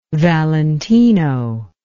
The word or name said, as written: Valentino